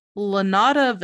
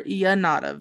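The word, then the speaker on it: Llano: The house was known then as, so it's LL. I'm pretty sure it's Spanish, so I'm going to pronounce it the really white, uneducated way, and then I'm going to try to pronounce it the correct way. – Okay. – So, it's either Lanada Village or Yanada Village. Or, sorry, Lanada Villa or Yanada Villa. – Yeah. – There you go. So, Yanada, as I'm sure it's actually pronounced, translates into flat ground or plains, and it later becomes known as the Winchester Mystery House.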